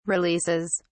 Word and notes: releases: British English

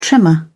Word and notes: tremor: British English

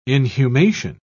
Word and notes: inhumation: British English

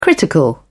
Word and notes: critical: British English

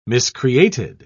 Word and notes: miscreated: British English

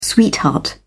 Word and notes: sweetheart: British English

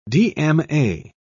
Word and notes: dma: British English

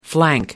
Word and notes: flank: American English

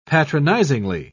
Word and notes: patronisingly: British English

